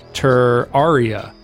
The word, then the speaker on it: Terraria: He's heard it pronounced Terraria and Terraria. But I think Terraria because of terrarium.